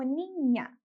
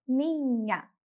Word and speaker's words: niña: E por último, a palavra niña, que significa menina, mais criança. Vai ser pronunciada como niña. Mais uma vez, niña.